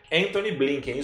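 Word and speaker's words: Antony Blinken: Anthony Blinken. Vou até conferir o nome, eu acho que é isso aí. Se eu não falei errado, né? Anthony Blinken, isso mesmo.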